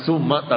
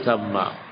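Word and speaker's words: thamma (ثَمَّ): Jangan salah bacanya, bukan summa tapi thamma.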